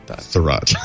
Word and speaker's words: Thurrott: S-A-D-E. Mary Jo Foley. – Sade. – It's spelled Sade, but it's pronounced Thurrott. – Thurrott.